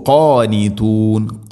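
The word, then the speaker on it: ഖാനിതൂൻ (قانتون): ഓതേണ്ടത് കുല്ലുൻ ലഹു, കുല്ലുൻ ലഹു അല്ല, കുല്ലുൻ ലഹു അല്ല, കുല്ലുൻ ലഹു, കുല്ലുൻ ലഹു ഖാനിതൂൻ, ഖാനിതൂൻ. ഖാഫ് തൊണ്ടയുടെ മുകൾ ഭാഗവും കഴിഞ്ഞ് അപ്പുറത്ത്.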